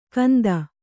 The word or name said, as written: ಕಂದ